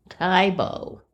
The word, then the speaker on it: Tae Bo: Taibo, T-A-E-B-O, is a workout created by Billy Blanks and is a portmanteau of Taekwondo and boxing. – Oh, Taibo. – Taibo. – Not Taibo.